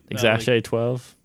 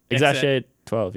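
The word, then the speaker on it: X Æ A-12: Because it's letter X, and then the AE said is Ash. – Yeah. – And then it's A12 for the plane.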